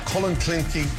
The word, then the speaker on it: Clonakilty: Irish tea bags or Clonakilty sausages from Cork. – Clonakilty. – Clonakilty. – Clonakilty, thank you.